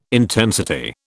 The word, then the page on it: intensity: British English